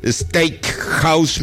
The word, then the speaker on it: steakhouse: el mejor steakhouse mexicano. Ahora sí lo dije bien. – Sí, ya tu inglés va mejorando.